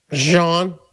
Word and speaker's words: Jean: Some people call you John? – Jean. – Jean. Like French pronunciation.